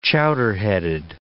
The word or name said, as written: chowderheaded